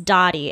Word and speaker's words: Dottie: Her mom's name was Odile, but they called her Dottie, which I'm assuming is pronounced Dottie. It's spelled D A D I, um, but I'm not about to be calling her Daddy, so I'm going to say Dottie.